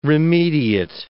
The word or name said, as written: remediate